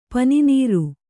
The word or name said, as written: ಪನಿನೀರು